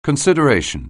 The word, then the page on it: consideration: anglais